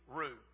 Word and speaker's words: Rieu: In conclusion, Dr. R.V. Rue, and it's spelled R-I-E-U.